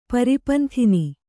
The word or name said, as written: ಪರಿಪಂಥಿನಿ